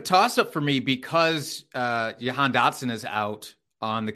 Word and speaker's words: Jahan: He says Jahan. It is Jahan.